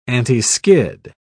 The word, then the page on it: antiskid: British English